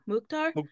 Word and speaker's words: Mukhtar: And he will be going up against Andre Blake, Sebastian Derussi, Chicharito, and Hany Mukhtar? – Mukhtar.